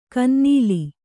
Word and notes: ಕಿನ್ನೆಲ: kinnela

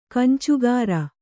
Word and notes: ಗೊಂಚಿಗಾರ: goncigāra

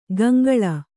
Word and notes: ಗಂಗಳ: gaŋgaḷa; Kannada

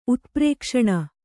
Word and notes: ಉತ್ಪ್ರೇಕ್ಷಣ: utprēkṣaṇa

